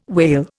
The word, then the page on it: wale: Indian